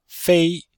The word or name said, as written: fei1